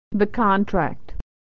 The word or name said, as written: the contract